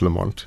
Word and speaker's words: Lamont: Yes, Lamont is a Southern Highland clan, so it's not Lamont, it's actually Lamont, which is mispronounced south of the border all the way down to Cape Town.